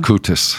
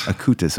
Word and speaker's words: Acutis: Acutis. – Acutis, okay.